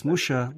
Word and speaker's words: Mucha: It's a little more complicated in the sense that a lot of the Japanese public thinks that Mucha, whose name they pronounce as Musha, was French.